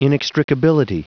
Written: inextricability

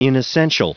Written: inessential

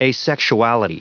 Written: asexuality

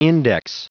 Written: index

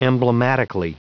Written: emblematically

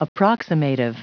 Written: approximative

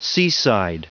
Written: seaside

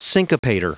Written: syncopator

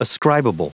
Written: ascribable

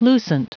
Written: lucent

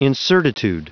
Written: incertitude

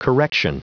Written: correction

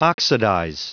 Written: oxidize

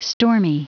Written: stormy

